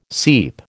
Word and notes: seep: American English

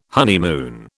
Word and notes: honeymoon: British English

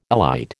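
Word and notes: élite: American English

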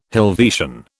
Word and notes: Helvetian: British English

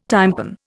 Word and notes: tympan: Indian

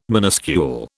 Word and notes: minuscule: British English

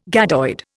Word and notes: gadoid: Indian

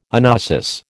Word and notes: anoesis: American English

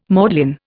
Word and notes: maudlin: Indian

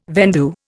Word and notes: vendue: Indian

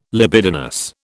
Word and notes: libidinous: British English

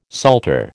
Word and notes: psalter: American English

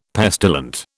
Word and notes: pestilent: British English

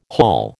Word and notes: hall: American English

